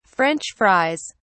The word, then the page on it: french fries: British English